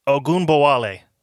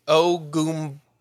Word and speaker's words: Ogunbowale: Yeah, I believe it's pronounced Ogunbowale. – Agunoble. – Yes. Okay. If I'm incorrect, then I apologize. That is my best effort. – Aaron does say in chat, Ogunbowale.